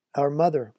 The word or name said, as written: our mother